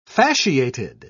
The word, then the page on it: fasciated: British English